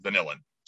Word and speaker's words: vanillin: most bourbon contains a good amount of vanillin, or the common pronunciation, vanillin.